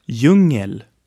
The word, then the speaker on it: djungel: Jungle in Swedish is djungel, and is written with D and J in the beginning. The D is silent, so we have djungel. Say after me, djungel.